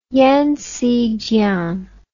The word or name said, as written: yangzi jiang